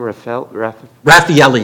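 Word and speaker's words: Rafaeli: Because the Michigan, how do you pronounce the name, Raffaele, Your Honor.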